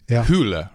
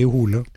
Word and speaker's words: Hole: So you've mentioned Harry, it's spelled H-O-L-E, so I think you know, an American would pronounce it Harry Hole, but it's Hule, right? – Yes, exactly. Harry Hule.